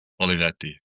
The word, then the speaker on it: Olivetti: give me the Italian version. – Olivetti. – Okay, that's way better.